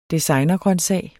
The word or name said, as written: designergrøntsag